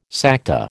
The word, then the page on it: Sakta: American English